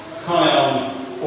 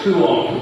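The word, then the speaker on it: kuon (κύων): So to begin with, the usual word in Greek for a dog in the Bible is variously pronounced as kuon, kion, or kion. Now, traveling with our grammar in the week, so the consensus is that kuon would usually be the consensus pronunciation.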